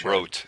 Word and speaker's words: Rot: Rote. It's pronounced Rote. That I know for sure.